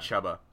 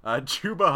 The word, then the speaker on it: Chuba: Chuba Hubbard, it's Chuba, right? It's not Chuba, because Chuba Hubbard would be way too much fun. Not a chance. His name's Chuba Hubbard. Chuba Hubbard, running back out of Oklahoma State.